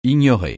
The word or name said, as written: ignorer